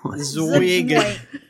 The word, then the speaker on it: Zuggtmoy: How do you think this is pronounced? Z-U-G-G-T-M-O-Y. – Zugtmoy? – Z-U-G-G-T. – Zugtmoy. – M... Oh, why? I think it's like Zugtmoy. – Zugtmoy.